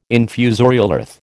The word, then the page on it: infusorial earth: American English